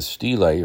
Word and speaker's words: stelae: Presumably, the stelae, or steel, is pronounced either way,